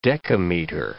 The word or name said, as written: dekameter